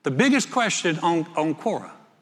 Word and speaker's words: Quora: I said, Quora. I think it's Q-U-O-R-A. I said, nobody knew how to pronounce it. It's Quora, right?